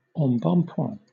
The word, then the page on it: embonpoint: British English